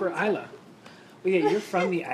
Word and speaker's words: Isla: Isla, you dumbass. – Isla? – The S is silent. – It's like island.